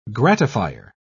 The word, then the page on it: gratifier: British English